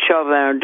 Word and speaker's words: Chauvin: damage to George and did Chauvin do it? Excuse me if I pronounce it the way I am, but I'm pronouncing it the French way.